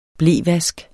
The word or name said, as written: blevask